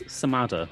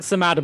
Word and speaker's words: Samadder: Before I do introduce you, though, how do you say your surname? Because people say mine wrong all the time when they introduce me, so I wanted to check I'm saying it right. – I'm not really sure. I guess Samadder. – We'll get as close as we can, eh? – I think Samadder, but only because that makes my punning Twitter handle work.